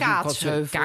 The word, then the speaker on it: Kaatsheuvel: Kaatsheuvel? – Kaatsheuvel, ja. – Dit is, er is zo'n lettergreep, nadruk, hoe noem je dat ook alweer? – Fout, gewoon. Het is fout, zoals jij het zegt.